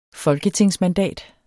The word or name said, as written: folketingsmandat